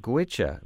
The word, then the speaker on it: Goitse: It comes off of the self-titled CD by the traditional Irish band from Ireland called Gwicha, which is spelled G-O-I-T-S-E.